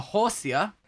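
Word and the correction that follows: Horsea: Horsey. It's pronounced a Horsey. – It's like a seahorse, but backwards.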